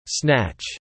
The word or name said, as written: snatch